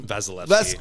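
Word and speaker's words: Vasilevskiy: Vasilevsky.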